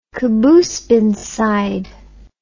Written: Qaboos bin Said

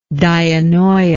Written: dianoia

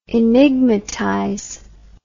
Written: enigmatize

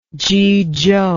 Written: zhuzhou

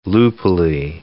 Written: loopily

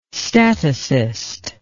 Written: statocyst

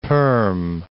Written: Perm'

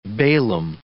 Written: Balaam

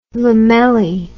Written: lamelli

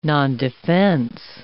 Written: nondefense

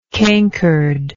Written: cankeredness

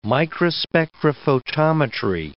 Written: microspectrophotometry

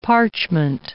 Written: parchment

